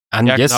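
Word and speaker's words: Agnès: Agnes wird sie tatsächlich ausgesprochen in der englischen Sprachausgabe.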